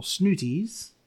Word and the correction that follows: Snootles: No, Snootles.